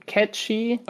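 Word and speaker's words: Cait Sith: It seems it seems dumb, and I think the Japanese pronunciation is still closer to Ketchi.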